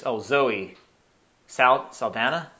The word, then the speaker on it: Zoe Saldana: The actress, Zoe Saldana? Did I say that right? That plays Gamora? – Yes.